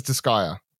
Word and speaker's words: Disgaea: He says Disgaea.